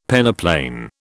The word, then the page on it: peneplain: British English